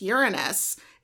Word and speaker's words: Uranus: Actually, Sophia tried to correct me and she said it's Uranus.